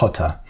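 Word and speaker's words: Rota: Yeah, so firstly, Ruta means root. And so it's R-O-T-A, but pronounced more, as you said, like Ruta. – Mm-hmm. Or really, in Portugal, it's, I think most Portuguese accents have more of a guttural sound, so it's more like Ruta.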